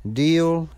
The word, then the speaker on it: Deale: Well, carrying on, we're going to hear from a couple of guys from Deal, Maryland. That's spelled D-E-A-L-E.